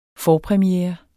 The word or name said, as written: FORPREMIEREN